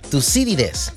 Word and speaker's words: Tucídides: Tucídides, así es, ¿verdad? Con acento en ahí, pero en la primera ahí. Tucídides.